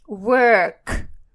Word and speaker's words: work: А работать по-английски произносится work, work. Это звук э, э, э, как в слове птичка, bird, bird, work.